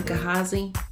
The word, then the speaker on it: Gehazi: In today's episode, we're considering a little-known servant by the name of Gehazi. Some pronounce his name Gehazi, but no matter the pronunciation, the story does not change.